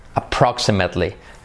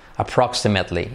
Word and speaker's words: approximately: Approximately. Otra vez la R, y la L que está por ahí. Approximately. Y la I como una E. Approximately.